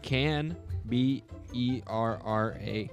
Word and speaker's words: Canberra: Canberra. It's spelled Can B E R R A.